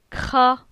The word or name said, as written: Cra